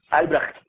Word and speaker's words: Albrecht: Por eso no puedo pronunciar su nombre. A-L-B-R-S-H-T. Albrecht.